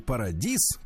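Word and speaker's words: Paradis: Э, действительно, Владик правильно сказал, немножко грустный для нас, для старичков день, потому что Ванессе Парадис, как мы ее называли, а на самом деле С в конце читать не, не надо, читается, Парадис, Э, Паради.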